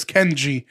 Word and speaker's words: Kenyi: The reason is with Too Skinny is because when I first started comedy, I was going as Too Skinny because my full name is Ken G, but it's spelled K-E-N-Y-I. So if I say, hey, you guys have been an amazing crowd, my name's Ken G Flores,